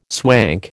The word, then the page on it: swank: American English